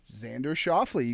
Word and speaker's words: Xander Schauffele: So I think I'm saying his name right. It's Xander Schauffele. – Xander Schauffele, you got that one right. Actually, yep. That's very good.